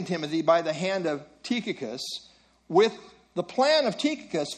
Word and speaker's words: Tychicus: I always pronounce this Tychicus, but when I listened online to how you should officially pronounce it, they said Tychicus. So, I guess it's Tychicus.